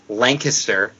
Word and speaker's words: Lancaster: And it's Lancaster. That was like the first thing I was told when I moved here. They're like, just so you know, everybody pronounces this place wrong.